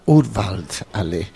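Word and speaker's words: Urwald Allee: En alemán se pronuncia Urwald Ale, esto significa avenida de la selva. – De la selva, Urwald Ale.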